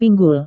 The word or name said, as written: pinggul